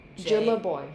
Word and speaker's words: Jumabhoy: Jumabhoy. – Yeah. So the H is silent. That's why I get a lot of Jumabhoy. but the A is before the B, so it doesn't really make sense.